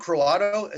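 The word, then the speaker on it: Croatto: There's one guy, I think it's Pete Croato, is how you pronounce his last name.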